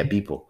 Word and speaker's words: Beeple: The art piece was called Every Day, the First 5,000 Days, and was created by Beeple. Am I saying that right? Yeah, Beeple. And is a collage of all the art he had uploaded since 2007.